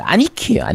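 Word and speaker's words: 兄貴: 형귀가 우리 흔히 말하는 일본어로 발음하면 아니키예요. 아니키. 네, 아니키.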